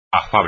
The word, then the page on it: afable: espagnol